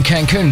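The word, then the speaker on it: Cancún: He's off on holiday to Cancun or Cansun. I'm not sure how to pronounce that. I didn't have time to look it up. – You mean Cancun, don't you? – Maybe so. Yeah, Cancun.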